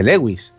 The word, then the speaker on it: Lewis: me estaba diciendo Germán desde, el este, este estudio que este, este escritor, CS, yo lo he, lo he mm, pronunciado en castellano, es realmente no este es C.S. Lewis, este es C.S. Eh, eh, Luis. Pero, mm, si acaso a alguien le interesa, eh, verdaderamente no se escribe Luis, se escribe L-E-W-I-S.